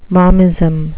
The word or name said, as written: momism